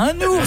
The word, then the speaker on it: un ours: On dit bien un ours. Ça s'écrit N-O-U-R-S. – On fait la liaison. – Un narval. – Un oiseau, N-O-I-V.